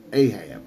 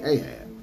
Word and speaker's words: Ahab: Ahab, if you want to pronounce it that way, Ahab.